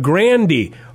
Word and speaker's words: Grande: She said it actually rhymes with candy. Ariana Grande.